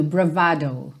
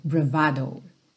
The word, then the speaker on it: bravado: Então, como ela vem do italiano, a pronúncia fica ali no meio do caminho, bravado, bravado.